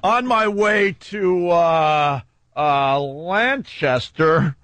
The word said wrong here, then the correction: Lancaster: No, you said it wrong. It's Lancaster. Right.